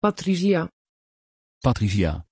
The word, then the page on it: patrizia: Dutch